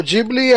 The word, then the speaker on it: Ghibli: a pronúncia correta é, é Ghibli, é Ghibli. mesmo.